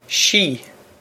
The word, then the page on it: sí: she